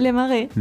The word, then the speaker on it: Les Marais: Les Marais.